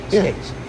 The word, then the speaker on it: Skeates: Steve Skeets. – Skates. – Skates. I say Skeets. – You say Skeets, but you say it wrong. – S-K-E-A-T-E-S. – S-K-E-A-T-E-S. Skates. – Yeah. – Oh. He pronounced it Skates.